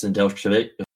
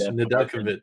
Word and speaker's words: Nedeljkovic: And speaking of players getting reassigned to Charlotte, Alex Nedeljkovic. I can't even fucking say it right now. My brain is fried. – Nedeljkovic. – There you go.